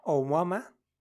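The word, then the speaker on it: Oumuamua (ʻOumuamua): O-U-M-A-U-M-A-U. Oumuamua. M-U-A. Oumua. Ajá, me equivoqué, bros. Pero bueno, así se llama, Oumuamua, por el lugar donde fue avistado por primera vez.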